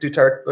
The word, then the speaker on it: Duterte: Duterte.